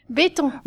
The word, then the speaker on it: Betton: Béton.